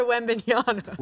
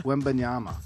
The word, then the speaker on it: Wembanyama: How do you say it? – Mama says tank for Wembanyama. – Wembanyama. I agree with that.